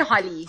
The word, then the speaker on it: Holi: How would you pronounce it there in India? – They say Holi.